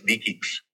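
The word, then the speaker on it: DE-CIX: It is pronounced with a K, so it's DECIX.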